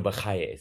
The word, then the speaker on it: Bachya: I'm talking about Bachya ben Asher ibn Halawa, who's more commonly referred to as Rabbeinu Bachya, or Rabbeinu Bachaye, some pronounce it.